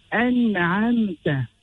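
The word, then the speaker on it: أنعمت: An-na'amta. The hamza originates from the bottom of the throat. The noon holds a sukoon.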